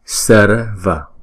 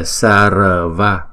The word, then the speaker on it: sarva: Rồi kế tiếp là chữ sarva, sarva. Không. Không phải là sarva. Chữ R này là không có đọc lên thành chữ R mà đọc là đánh lưỡi lên thôi.